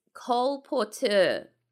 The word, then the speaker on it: colporteur: So C-O-L. – Seriously? – Yes, seriously. – It was colporteur song. – Yeah, no. So you spell it, it's one word. You spell it C-O-L-P-O-R-T-E-U-R, colporteur. Mm. Now, it might sound like we're trying to say Cole Porter in a French accent.